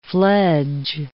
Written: fledge

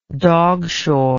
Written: dogshore

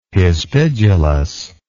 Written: hispidulous

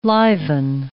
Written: liven

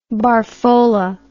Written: barfola

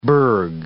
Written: berg

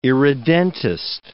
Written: irredentist

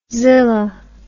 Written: zilla

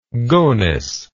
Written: gonys

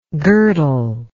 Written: girdlingly

